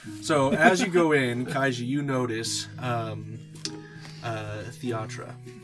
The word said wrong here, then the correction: Thyatra: Thyatra. – Thyatra. Thank you. There's no pronunciation there.